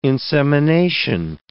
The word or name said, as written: insemination